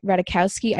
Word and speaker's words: Ratajkowski: We had Nicki Minaj, Emily Ratajkowski.